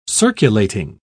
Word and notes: circulating: British English

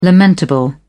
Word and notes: lamentable: British English

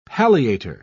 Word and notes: palliator: British English

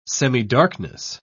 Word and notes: semidarkness: British English

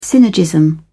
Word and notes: synergism: British English

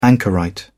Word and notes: anchorite: British English

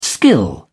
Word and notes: skill: British English

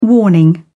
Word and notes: warning: British English

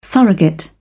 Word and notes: surrogate: British English

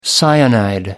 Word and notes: cyanide: American English

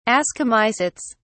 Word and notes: ascomycetes: British English

